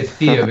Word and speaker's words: Vier: Now, is it pronounced Vier? Is that right? – Depends on what your nationality is. I say Vier because I'm German. – Okay. – And in German, the V is pronounced like an F. – Right. – But I guess generally you would say call it Vier, yes.